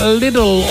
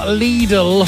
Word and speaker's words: Lidl: It was also the year that Lidl, or Lidl, opened its first stores in Britain, depending on how you say it. If you're common like me, you go Lidl. If you're proper, you say, oh, Lidl, oh.